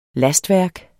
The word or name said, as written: lastværk